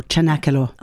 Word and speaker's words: Cenacolo: How do you spell it? – C-E-N-A-C-O-L-O, Chanakalo.